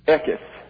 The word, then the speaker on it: Eketh: That's the official pronunciation, the TH rather than the D. I'll show you. This is a D without a dot, and that means it's TH. And therefore, it is Eketh.